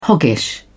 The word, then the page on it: hoggish: British English